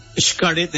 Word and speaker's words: Xcaret: Xcaret, ¿lo dije bien, amigo? – Xcaret, muy bien, lo dijiste Xcaret.